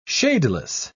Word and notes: shadeless: British English

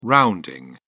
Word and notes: rounding: British English

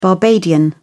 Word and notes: barbadian: British English

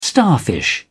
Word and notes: starfish: British English

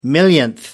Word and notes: millionth: American English

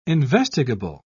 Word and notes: investigable: British English

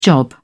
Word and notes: job: British English

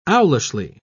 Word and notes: owlishly: British English

